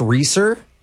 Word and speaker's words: Reeser: Yeah, my name is Alex Reeser. – Reeser. Can you spell that, please? – Yeah, R-E-E-S as in Sam, E-R.